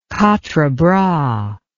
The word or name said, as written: quatrebras